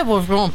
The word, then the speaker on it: Beaujean: Se escribe Beaujean con J. – Boyan, pero se pronuncia. Se pronuncia Boyan. Se pronuncia Boyan.